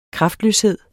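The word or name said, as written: KRAFTLØSHED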